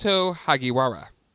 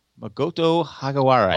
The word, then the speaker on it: Makoto Hagiwara: Maka Toto. – Let's see. Hold on. – Hagawara. Of Golden Gate Park's Japanese tea garden. – It's Makoto Hagiwara. – Makoto Hagiwara.